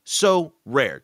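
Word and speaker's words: Sorare: They're our new sponsor, and it's pronounced So Rare.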